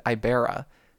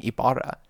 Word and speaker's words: Ibarra: So when I was growing up, me and my nuclear family always said Ibera. And then as soon as I got to BYU, with I'm from Ohio, from a very very white town, and then as soon as I got to BYU, everyone, all these RMs started saying Ibarra. And I was like, well, that's not how I say my name.